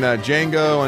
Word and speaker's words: Django: And then Django Unchained because the D is silent.